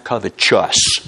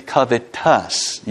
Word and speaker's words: covetous: Now, by the way, that's not pronounced covetous. There's no E after the T, as in righteous. It's covetous. You say, you're so smart.